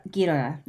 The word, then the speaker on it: Ghidorah: I think I said that right that time. Ghidorah. – Ghidorah, yeah.